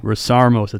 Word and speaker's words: Rasiarmos: Boom, live, another episode on Adversity Kings, episode 30-something, who knows, and we got Steve, how do I pronounce the last name? – Rasiarmos. It's a goofy Greek last name.